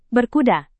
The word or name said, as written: berkuda